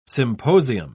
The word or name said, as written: symposium